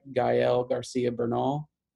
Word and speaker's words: Gael Garcia Bernal: So in addition to, am I saying this right? Gael Garcia Bernal. – Mm-hmm.